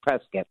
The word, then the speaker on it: Prescott: So Prescott is spelled P-R-E-S-C-O-T-T. Now, correct. – Is it Prescott or is it Prescott? – Depending on where you're from. I guess if it's California, it's Prescott. If you're from Arizona, it's Prescott. – Yes, Prescott. It's like biscuit, but you know, because I, you know, I always feel like, am I pronouncing it right?